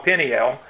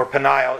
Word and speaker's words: Peniel: But then he came, especially when he came back to that place called Peniel, or Peniel, it's sometimes pronounced.